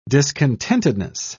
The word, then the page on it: discontentedness: British English